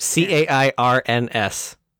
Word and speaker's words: Cairns: C A I R N S.